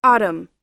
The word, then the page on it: autumn: American English